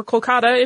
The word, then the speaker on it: Kolkata: So that is the rather jarring and horrible story of the black hole of Calcutta, or Kolkata, if you use the modern pronunciation, or the accurate pronunciation, I should say.